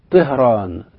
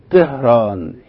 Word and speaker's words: طهران: لو استمعنا إلى واحد من الإيرانيين وهو يتحدث أو يذكر اسم عاصمته اللي هي طهران، هنبص نلاقيه بيقول عنها إن هي طهران طهران، يعني الطاء تكاد تصل في النطق إلى حرف التاء.